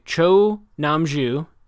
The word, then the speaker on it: Cho Nam-ju: by Cho Nam-ju. How did I do? Not good? – Yeah, it was good.